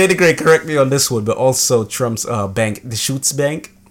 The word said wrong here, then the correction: Deutsche Bank: How's it pronounced? – I'm pretty sure it's Deutsche Bank.